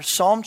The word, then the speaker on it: Psalm: I even remembered that there's a silent P in front of Psalm, and then I misspelled the rest of it, which is super embarrassing for someone who's real type A like I am.